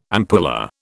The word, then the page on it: ampulla: British English